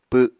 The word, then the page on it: pw: apatani